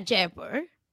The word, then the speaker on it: Jaipur: Yeah, Jaipur.